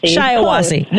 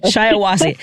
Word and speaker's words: Shiawassee: Really? Shiawassee? That's how you pronounce that? I was... – Shiawassee. – We'll get it. Very close. Shiawassee. Practice makes perfect, so hopefully we'll have many times to practice with you and get it right.